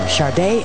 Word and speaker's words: Sade: As song number 3 is spelled Sade but pronounced Sade. Last name is A-D-U. Adieu. Sade.